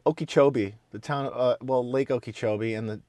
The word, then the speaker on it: Okeechobee: You're like, Okeechobee. It's Okeechobee.